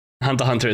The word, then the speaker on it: Hunter x Hunter: You don't pronounce the X. Like Hunter X Hunter.